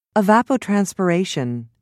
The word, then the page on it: evapotranspiration: American English